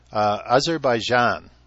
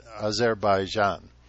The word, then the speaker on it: Azerbaijan: Azerbaijan is how it's pronounced, or Azerbaijan. Anyway, I spoke with this woman quite a bit, and her area of study was asteroids.